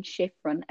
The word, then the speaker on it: Sheffrin: Now, just to make sure we've got the spelling of Denny's name right, it's D-E-N-N-I and then Sheffrin. And please tell me I'm pronouncing that right. S-H-E as in she, as in woman, and then F-R-I-N.